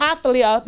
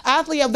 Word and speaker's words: Athaliah: Now, different accounts say different things, but the wickedness that you see from Athaliah, that's how you say her name, Athaliah, the wickedness you see, I am led to believe that Jezebel was her mother.